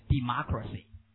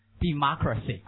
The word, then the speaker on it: democracy: No，错了，念 democracy， democracy 哈、啊，这个重音变化。